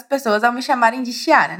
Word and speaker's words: Chiara: O que eu quero te contar, esclarecer aqui, é que em italiano esse CH jamais terá esse som de X que pronunciamos em português. É só você lembrar da prof aqui. Chiara é Chiara e não Chiara, só porque temos um CH ali.